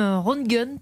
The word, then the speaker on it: Röntgen: Le physicien allemand Wilhelm Röntgen, pardon pour la prononciation. – Non, c'est très bien.